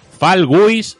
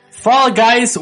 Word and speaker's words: Fall Guys: Fal, yo lo voy a decir lo más cateto o al, castellano posible, ¿vale? Tú ya lo dices súper pronunciado, que es el, el, inglés ese le mola el a, a Hugo. Fal Guis, un punto. Fall Guys, 1 point.